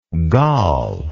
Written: galle

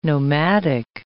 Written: nomadic

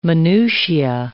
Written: minutia

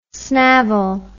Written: snavel

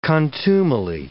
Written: contumely

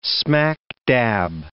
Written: smack-dab

